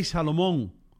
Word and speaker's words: Salomón: I don't read the Bible, pero estoy pensando que ese tipo, Rey Salmón, era pescadora. – Rey Salmón, no, hermano. No es Rey Salmón. Rey Salomón.